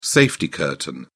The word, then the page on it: safety curtain: British English